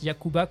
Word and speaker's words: Yacouba: Ah bah c'est parfait, vous linkerez vers Kitchenette, Yacouba comme ça se prononce, Y-A-C-O-U-B-A.